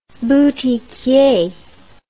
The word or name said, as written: boutiquier